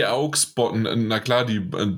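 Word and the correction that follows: Beauxbatons: die Beaubaton.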